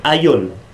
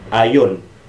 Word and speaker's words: aion: El primer tiempo es aion, se pronuncia así, aion.